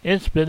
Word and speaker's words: insipid: Inspit is a word. Probably mispronouncing it. I like to mispronounce a lot of words. It's spelled I-N-S-I-P-I-D.